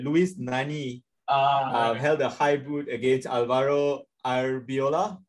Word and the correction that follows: Arbeloa: I can't really remember how to pronounce his name. Arbeloa, yeah, sorry.